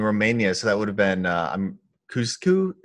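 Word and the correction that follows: Ceaușescu: am I pronouncing that? – Ceausescu. It's a hard one.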